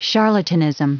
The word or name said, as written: charlatanism